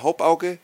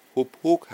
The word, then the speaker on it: Hauppauge: Und er sagte immer wieder Haupauge und Haupauge und Haupauge, obwohl die Firma eigentlich Hubhog heißt. So spricht man die korrekt aus.